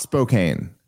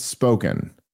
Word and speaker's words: Spokane: So no, what I'm told is it's not Spokane. It's Spoken.